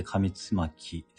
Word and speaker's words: 上巻: 上巻と書いて上つまきですね。